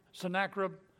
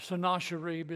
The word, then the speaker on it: Sennacherib: Sargon II has a son named Sennacherib, or Sennacherib is the way they pronounce it.